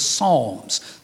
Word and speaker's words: Psalms: People love the book of Psalms. The P is silent, Psalms.